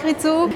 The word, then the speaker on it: Rizzo: Oui, très bien, avec les deux Z. Comme alors, pizza. Comme... Mais normalement, c'est Rizzo. On fait un petit S.